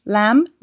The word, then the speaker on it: lamb: Comb, climb, lamb ve tom gibi MB kombinasyonu olan bir kelimenin sonundaki B harfi sessizdir.